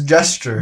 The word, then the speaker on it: gesture: It's a jester, bro. – It's a nice what? – Jester. – Bro, am I saying it right? – Who's jester? It's gesture.